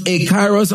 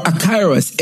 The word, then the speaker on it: akairos: And that either pronounced akairos or akairos, A-K-A-I-R-O-S. So you notice that same word there, but either E-U in or A-out. Right?